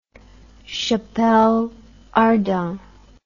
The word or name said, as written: chapelle ardente